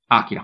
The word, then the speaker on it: Akira: Akira o Akira, qual è la pronuncia? Dimmelo subito così Akira. non sbaglio. – Akira. – Perfetto.